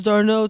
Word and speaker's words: Darnell: And I was like, yo, my name is Darnell, too. He's like, how you spell it? I was like, D-A-R-N-E-L-L.